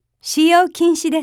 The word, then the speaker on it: 使用禁止: 使用禁止です。